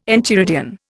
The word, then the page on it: enchiridion: Indian